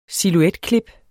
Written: silhuetklip

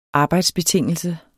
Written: arbejdsbetingelse

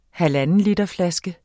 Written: halvandenlitersflaske

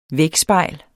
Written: vægspejl

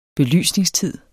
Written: belysningstid

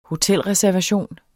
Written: hotelreservation

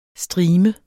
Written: STRIMERNES

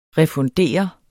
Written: refundere

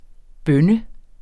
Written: bønne

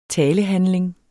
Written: talehandling

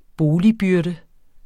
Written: byrder

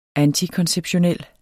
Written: antikonceptionel

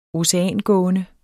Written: oceangående

